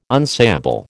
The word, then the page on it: unsayable: American English